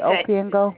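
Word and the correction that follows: Opeongo: it's a native, like it's our native, our Aboriginal people. It's a native name. – Oh, okay. – So it's Opiongo.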